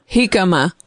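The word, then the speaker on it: jícama: Jicama.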